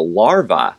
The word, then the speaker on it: larvae: They also are going after the larvae. – larvae is the French pronunciation, yes. – the French plural.